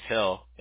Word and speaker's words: Hill: My name is Jonathan, but my last name's Hill. It's H-I-L-L. But everybody calls me John. – Okay. – Just J-O-H-N for sure.